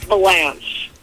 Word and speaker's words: Valange: It's Valange. – Valange. Bruce Valange. Right. Just like it looks. – Yeah. – Right. – Yeah. And so, I mean, it's just so people pronounce it. They're like, oh, you're with Bruce Valange. I'm like, you know, he's not French.